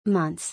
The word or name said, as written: Months